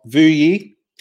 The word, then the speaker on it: Vuyi: This week, you've got Vuyi.